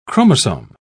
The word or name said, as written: chromosome